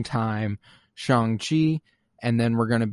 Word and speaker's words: Shang-Chi: Yeah, Shang-Chi. He was referred to by Wong as Shang-Chi, so I'm comfortable going with the ch sound for chi. I do know, however, it is Shang.